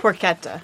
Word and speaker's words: porchetta: Porchetta.